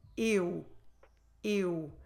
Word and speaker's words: eu: So the sound we will need to do is exactly the sound we do when we pronounce the word for I in Portuguese, eu. Start with your mouth open and then close it, close your lips and make them round in the end.